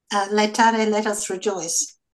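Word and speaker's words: Laetare: Letare, I think.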